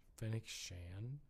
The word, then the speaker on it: Fennec Shand: Fennec Shand. Is that how you say her name? – Shand. – Fennec Shand. There we go.